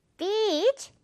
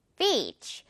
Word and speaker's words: beach: 입 길게 미소 발음 이 beach 찢어줘야 돼요. beach. 이것도 여러분이 정색하고 발음하면요. 영어에 그 발음이랑 비슷한 속어가 있기 때문에 오해를 살 수 있으니까 꼭 주의를 해야 돼요.